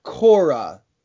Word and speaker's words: Kore: so it's spelled K O R E, and it's pronounced Kora from the Greek word, which means a young woman.